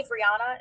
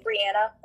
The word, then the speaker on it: Brianna: because people call me Brianna, and that's not my name. So okay. it's Brianna, like Anna, and everyone says it wrong, so I just go by just Bri.